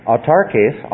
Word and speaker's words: autarkes: So we get the emphasis in the right syllable, right? Okay. So the Greek word there is autarkes, autos archeo, means self-suffice.